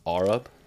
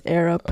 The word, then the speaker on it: air up: Is it not pronounced R up? – No, it's air up.